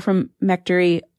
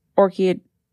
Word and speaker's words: orchiectomy: And one of the things is not performing surgeries to sterilize, including castration, vasectomy, hysterectomy, or from mectory, orchiectomy.